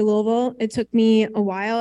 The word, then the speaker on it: Louisville: I used to say Louisville, but they said, no, you got to swallow it and say Louisville.